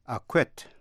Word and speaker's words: acquit: Quý vị nghe ba chữ acquit, acquire và acquaintance có một điểm chung giống nhau. Đó là gì? Quý vị có trả lời là âm si câm. The si is silent. Động từ acquit là tha bổng.